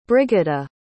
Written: Brigida

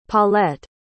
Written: paulett

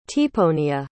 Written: tiponya

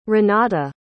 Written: renata

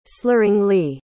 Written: slurringly

